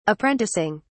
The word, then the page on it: apprenticing: British English